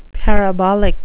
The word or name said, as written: parabolic